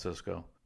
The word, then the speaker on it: Sysco: S-A-B-I-N-E, Pass Liquefaction, Southwest Airlines, Cisco Corporation, S-Y-S-C-O, that's Cisco, Toyota Motor Credit Corporation, UPS, United Health Group, Virginia Electric and Power, Visa, VMware, Walgreens Boots Alliance, Walmart, and Williams Companies.